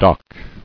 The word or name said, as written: Docking